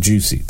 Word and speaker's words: Jussie: But if you actually look at how it's spelled, J-U-S-S-I-E, it's pronounced Juicy.